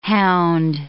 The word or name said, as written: hound